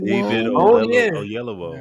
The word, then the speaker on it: Oyelowo: either Omar Hardwick or David Oyelowo. – Oyelowo.